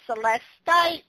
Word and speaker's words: celestite: I call it celestine, but I guess some people call it celestite. Is that how you pronounce it? – Celestite, yeah, or celestine, either way.